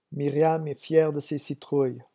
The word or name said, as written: Myriam